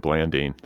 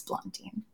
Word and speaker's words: Blandine: I think I said Blandine. That's a very Indiana pronunciation. – I bet most people in Indiana would say Blandine. – Okay. – But Blondine. I thought she would be like, no, it's Blondine.